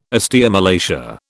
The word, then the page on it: osteomalacia: British English